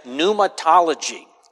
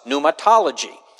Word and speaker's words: pneumatology: The study of the Holy Spirit is known as pneumatology. It is spelled with a silent P, pneuma, doxology. The reason for that is the Greek word for spirit is the word pneuma, thus pneumatology. This particular Greek word pneuma can be translated as spirit.